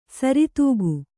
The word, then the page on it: ಸರಿದೂಗು: saridūu